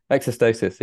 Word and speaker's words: exostosis: We talked about money, we talked about life, children, hearing loss, exostosis. Is that how you say it? – Exostosis, yeah.